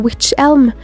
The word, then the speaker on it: Wych Elm: På engelsk heter almetreet witch elm, og selv om det ikke staves likt som det engelske ordet for heks, altså witch, uttales det likt, og ryktene om at kvinnen var en heks, blusset opp.